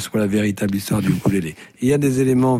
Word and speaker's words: ukulélé: Au fait, ça se prononce comment On dit ukulélé, je crois, à la mode hawaïenne.